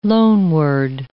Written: loanword

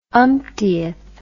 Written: umptieth

